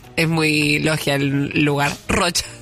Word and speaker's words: Rochas: Bueno, Rochas. Rochas.